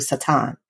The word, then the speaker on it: Satan: But at the end of the day, we all have one enemy and one enemy alone, and that's Satan, or how most people would pronounce him in the Hebrew, Satan.